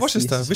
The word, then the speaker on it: Worcester: Wie spricht man die Winchester, Worcester Soße heißt ah, Ja, Worcester. Worcester.